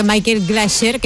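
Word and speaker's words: Michael Glaser: Y creo que Michael Glasher, que me están chivando por ahí, era Starkey, ¿no? ¿Lo he dicho bien? Sí.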